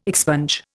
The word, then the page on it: expunge: Indian